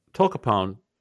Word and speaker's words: Tolcapone: Tolcapone. And we hope we're saying it correctly because this is the first time I've ever heard of this drug. But that it's spelled T-O-L-C-A-P-O-N-E. Tolcapone is what we're saying.